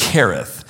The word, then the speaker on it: Cherith: It can also be pronounced Cherith, "which is east of the Jordan.